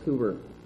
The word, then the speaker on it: Cowper: It's spelled Cowper, but it's pronounced Cooper.